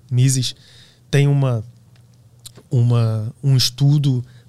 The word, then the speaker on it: Mises: Mises.